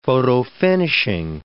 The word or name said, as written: photofinishing